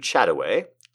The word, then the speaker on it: Chataway: This is from Lewis Carroll to Gertrude Chataway. Which is spelled exactly like it sounds, one word, C-H-A-T-A-W-A-Y, Chataway.